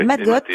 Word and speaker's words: Matcotte: Alors, la galerie Matcotte, c'est ça ? C'est comme Matcotte, ça qu'on... Tout à fait. Matcotte.